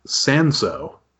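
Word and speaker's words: Cinsault: It's C-I-N-S-A-U-L-T. If I were guessing, I would say Sinsalt, but I don't know if that's a hard or a soft C. But it's from South Africa, and I figured I could tie it into this episode by saying that Angelica was on there. So I'm celebrating Angelica's place of origin. – That's a good point. Would you believe the pronunciation, according to Wikipedia, is Sanso? – Oh, okay.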